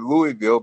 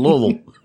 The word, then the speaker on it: Louisville: And I always pronounced it Louisville because when I lived in Kentucky, that's how they pronounce Louisville on basketball games, mm-hmm. – the Louisville Cardinals. It wasn't until I moved to Louisville that I learned how Louisvillians. – That's right. It's Louisville. You got to swallow in the middle of it.